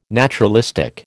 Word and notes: naturalistic: American English

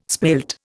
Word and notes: spilt: Indian

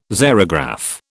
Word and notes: xerograph: British English

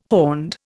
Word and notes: horned: Indian

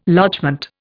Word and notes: lodgement: Indian

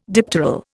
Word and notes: dipteral: Indian

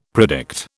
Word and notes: predict: British English

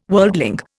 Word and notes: worldling: Indian

